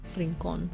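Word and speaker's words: Rincón: Say it in Spanish. Rincon.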